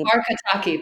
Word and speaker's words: Barkataki: I'm not going to pronounce her last name correctly. – Barkataki. Bart- Barkataki. Barkataki. Thank you.